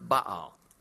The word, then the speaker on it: Baal: Baal. More correctly pronounced, Baal, that jumps out at you from the Old Testament and the problems with the nation of Israel, Canaanite worship of Baal there.